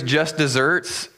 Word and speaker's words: deserts: When the phrase just desserts, it's pronounced like desserts with two S's, but it's spelled like desert with one S.